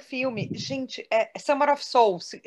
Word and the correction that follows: Summer of Soul: desculpa, falei errado. – Summer of Soul, é, de alma, é.